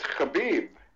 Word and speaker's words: Khabib: How do you say Khabib Nurmagomedov? Slide on the correct way. – It's Khabib. Khabib. K-H.